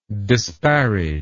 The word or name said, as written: disparager